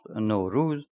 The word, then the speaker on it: Nowruz: The following are a few facts and stories about the Baha'i New Year called Nowruz. It is correctly pronounced Nowruz, meaning New Day.